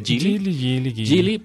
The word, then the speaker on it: Geely: Geely,